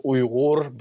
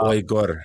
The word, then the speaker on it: Uighur: So it's officially, like, the way we pronounce it is Uighur, but it's very difficult to pronounce for Uighur.